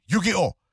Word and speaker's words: Yu-Gi-Oh!: Yu-Gi-Oh!